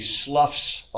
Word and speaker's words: slough: Um, the word S L O U G H, when used for a snake shedding its skin, he sloughs off his skin. Sloughs off. So it rhymes with the word enough or cough.